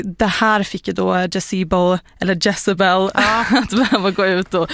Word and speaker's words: Jezebel: Det är den amerikanska tidningen Gazebo publicerade en artikel om att de hade anlitat Etsy-häxor. Det är väldigt svårt ord att säga. – Jezebel? – Ja, eller Jezebel kanske uttalas.